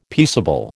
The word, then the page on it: peaceable: American English